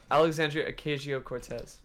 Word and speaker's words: Alexandria Ocasio-Cortez: Alexandria Ocasio-Cortez.